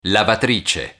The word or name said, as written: lavatrice